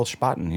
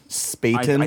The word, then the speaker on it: Spaten: So we've got the this green bottle Spaten here. Mm-hmm. I hope that I'm pronouncing it correctly. Spaten. – I think it's Spaten. – Spaten. – Well, I guess there's no umlaut over the A.